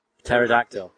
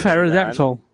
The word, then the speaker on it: Pterodactyl: Why the hell is the flying one spelt with a P? – Pterodactyl. – It's ridiculous. – Why not? – Well, why is it pronounced with a T? – Plenty of words that begin with Pterodactyl.